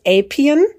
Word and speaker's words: Appian: Es wird ja nicht Apian geschrieben. Warum spricht man es Apian?